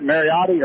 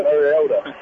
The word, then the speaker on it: Mariota: Is that Mariotti or how do you say that? – Mariota? – Mariota. You don't need to start anymore, does he? – No, he doesn't.